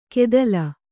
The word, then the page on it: ಗೆದಲ್: kannada